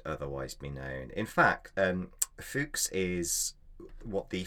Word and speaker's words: Fuchs: Leonard Fuchs is a 16th century Bavarian botanist, and I'm sure you would not be pronouncing his name Fuchs, F-U-C-H-S.